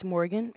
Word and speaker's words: Morgan: It's Morgan, M-O-R-G-A-N.